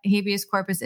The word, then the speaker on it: habeas corpus: Pretty sure I know what it entails, but could you explain for everybody what habeas corpus is? I think I'm saying that correctly. – You are.